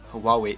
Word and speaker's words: Huawei: I looked at it myself, and the right way to pronounce Huawei is, what? Huawei. – That's, okay, thank you for telling me that, because I'm always like, how do you pronounce this phone? Huawei. So the Huawei. H is not really there, because the H, I'm always like Hawaii.